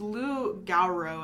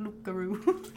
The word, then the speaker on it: loup-garou: It's loup-garou, I think. – I don't know. I like loup-garou. – You don't pronounce the P.